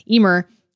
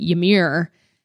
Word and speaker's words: Ymir: The author reads it, and this helps with the pronunciation of the names, which is why I asked Tom about the pronunciation of word YMR, Ymir, because I've been saying it Ymir, and that was wrong.